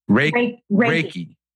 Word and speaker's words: Reiki: And we will cover that as we go, but another pronunciation that we need to get is the Reiki. – Reiki. Right. Reiki is an energy healing.